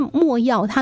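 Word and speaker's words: 没药: 可能朋友们会说，没什么印象哈、这墨药它那个字啊。就是有没有的没哈、哦，但是它是多音字，这里要念墨，药就是药品的药哦。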